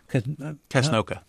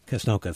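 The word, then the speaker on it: Casnocha: We're talking again with Reid Hoffman, who along with Ben Kasnoka. – Kasnoka, thank you. I thought that H might be, or that N might be pronounced in a different way. Actually, it's a CH.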